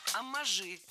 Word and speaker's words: оммаж: Потому что могут быть и вариации, и омажи, и какие-то цитирования. – Оммажи – это неверно. Слово «оммаж» имеет ударение на втором слоге, и при склонении ударение остается на том же месте. Оммажи, оммажей, оммажами.